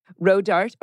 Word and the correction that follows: Rodarte: Rodarte. – Rodarte, sorry. – Rodarte. – Rodarte. I always said it wrong.